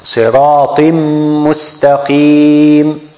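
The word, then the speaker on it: siratim mustaqim (صراطًا مستقيمًا): We deleted the noon and we put meem mushaddada on. We put shadda on the meem, and we pronounce it siratim mustaqim.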